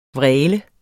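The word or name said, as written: VRÆLEDE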